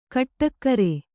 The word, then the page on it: ಗುಟ್ಟುಗಾರ: kannada